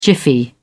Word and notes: jiffy: British English

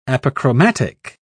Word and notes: apochromatic: British English

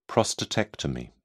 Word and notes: prostatectomy: British English

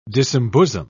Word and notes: disembosom: British English

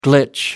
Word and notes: glitch: American English

